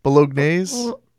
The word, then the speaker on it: bolognese: Oh God, it's a recipe for spaghetti bolognese. How do you say it? Bolognese? – No, bolognese.